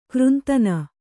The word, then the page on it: ಗ್ರಂಥನ: granthana